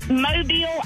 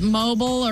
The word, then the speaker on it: Mobile: And didn't you hate when people called it mobile or mobile? – Yeah, and I have to correct them real quick. The emphasis is on the end.